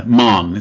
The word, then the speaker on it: Mahon: Mahon, it's one syllable. It's almost, pretend the O isn't there.